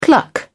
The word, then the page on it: cluck: British English